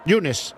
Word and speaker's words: Younes: Yunes. Vale. Como el primer día de la semana en asturiano. Yunes. Aunque se escribe Younes, se pronuncia Yunes.